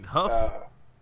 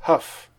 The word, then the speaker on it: Hough: You mean Huff? – Is a I never know how to pronounce it. Yeah. – Yes. – Huff.